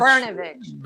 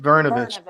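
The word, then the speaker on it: Brnovich: So there was been criminal referrals to uh, how do you pronounce his name? Bronovich. – Bernovich. – Bernovich. Bernovich.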